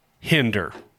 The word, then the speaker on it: Hinder: So, a band like Hinder. – Rhymes with Tinder.